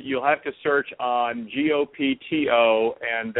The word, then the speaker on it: Gopto: But the the book is called Gopto, and it's spelled G O P T O.